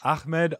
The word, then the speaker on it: Ahmed: Ahmed. That is the proper way to say it. Ahmed Al Qadri.